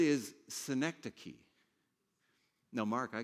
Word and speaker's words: synecdoche: Synecdoche. Say this. Synecdoche.